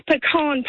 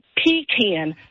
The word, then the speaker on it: pecan: It's pecan pie, not pecan. A pecan is a porta potty, not something you eat.